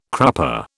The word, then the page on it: crupper: British English